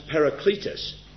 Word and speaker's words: parakletos: That word advocate there is a Greek word, I'll spell it for you, pronounced parakletos. Spelled P-A-R-A, para, word which means beside, and kletos, K-L-E-T-O-S, to aid or help. So parakletos, P-A-R-A-K-L-E-T-O-S, means to be called to one side.